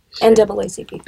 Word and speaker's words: NAACP: Like the NACCP, did I say that right? – NAACP.